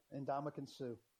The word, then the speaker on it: Ndamukong Suh: We'll get into the potential interest of, and bear with me, Ndamukong Suh. Did I say that right? – You nailed it there. Yeah, Ndamukong Suh.